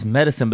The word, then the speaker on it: Medasin: Got me saying like, and his name is Medicine, but it's spelled M-E-D-A-S-I-N.